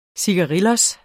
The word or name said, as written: cigarillo